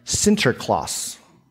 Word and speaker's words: Sinterklaas: Saint Nicholas, or in Dutch, it's, it's pronounced, or it's, it's, it's said Sinterklaas, hence where we get Santa Claus from.